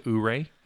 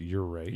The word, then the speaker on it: Ouray: You could drive that to Ouray. It's beautiful. – It's actually pronounced Ouray. – Ouray. Yeah.